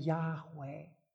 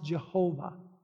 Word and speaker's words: Yahweh: When the word Lord, every letter is capitalized, that's the name Yahweh. Mispronounced Jehovah.